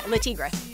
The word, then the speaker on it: Le Tigre: This is Decepticon by, how do you say the name? Le Tigre? – Yeah, Le Tigre.